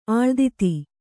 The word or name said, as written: ಆಳ್ದಿತಿ